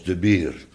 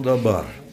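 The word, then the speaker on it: de bier (דבר): hebben afgesproken dat je het moet uitspreken als de bier. Vanwege het onderscheid. Maar sommigen onder ons denken dan aan een kroeg. Dat is het niet, hè. Ja, het is gewoon dabar, woord.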